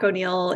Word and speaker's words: O'Neill: Am I saying it right? – I yeah, O'Neill. O'Neill, yeah. yeah.